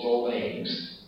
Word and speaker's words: jovens: Aquí jovens, pronunciado agudo, jovens,